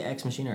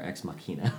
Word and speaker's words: Ex Machina: But now, do they say Ex Machina or Ex Machina? – I've heard it pronounced both ways. – Okay. – I guess Ex Machina would be the Latin way.